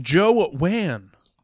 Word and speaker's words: Jowan: And we're also still here with Joe Wan.